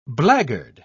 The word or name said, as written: blackguard